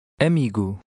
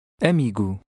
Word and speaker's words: amigo: Amigo. Amigo. – The Portuguese O often sounds like U when it is at the end of a word and when it is not stressed.